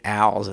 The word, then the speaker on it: Als: He prefers the pronunciation Als as opposed to all, so I just thought I'd say that since it rhymes with me anyway. Hilton Als.